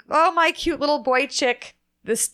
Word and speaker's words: Boychik: So it's Boychik Bagels, and it's spelled B-O-I-C-H-I-K. And it's a Yiddish word. It's normally spelled B-O-Y-C-H-I-K. And it's pronounced Boychik, and in Yiddish, it means cute little boy.